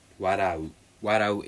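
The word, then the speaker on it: warau (笑う): Warau is the verb for it. So, and warau is spelled like w-a-r-a and you.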